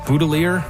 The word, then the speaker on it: Boutillier: Goldie Boutillier, originally from Nova Scotia, where most people pronounce it Boutillier.